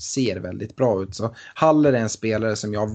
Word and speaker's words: Haller: Men Haller, eller Haller som jag kanske har förstått att det ska uttalas nu, fransman som han är.